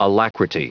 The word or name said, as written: alacrity